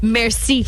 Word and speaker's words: MRSA: Mercy. – Mm-hmm. Yeah, Mercy. Yeah, that's it. Like Mercy Feet. – Like MRSA. – MRSA Feet. – It's Mercy.